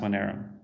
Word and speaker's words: Monero: The shortened like token ticker, I guess, like the stocks is XMR, but it's pronounced Monero.